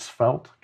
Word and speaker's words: Svelte: Is it Svelte, guys? – Yep.